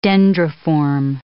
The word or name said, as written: dendriform